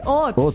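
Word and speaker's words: haute: Es que es H-A-U-T. Ah, ¿eso no es francés? Hot. Sí, eso es francés.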